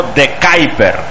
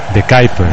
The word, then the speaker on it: De Kuyper: Entonces sabe cómo se pronuncia. Y ha dicho algo así como De Kuyper. – De Kuyper.